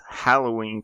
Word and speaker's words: Halloween: And then we're going to talk about how it's pronounced Halloween because it comes from All Hallows Eve and it's spelled with an A.